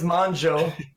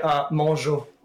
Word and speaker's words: Mongeau: It's French, and in French you would say Mongeau.